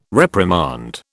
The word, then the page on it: reprimand: British English